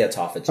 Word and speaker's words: autophagy: I've heard it pronounced two ways, autophagy or autophagy. Okay. How do you pronounce it? – I say autophagy.